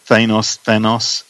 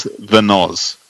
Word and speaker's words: Thanos: Is it Thanos? I will correct you all now. It is the Noz.